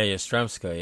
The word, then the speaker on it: Yastremska: Let's see if I can pronounce this, Yastremska. – Yeah, Yastremska.